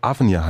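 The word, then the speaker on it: Avnir: Der äh, Chemiker David Avnia heißt der, ne? oder wird es so ausgesprochen? – Ja.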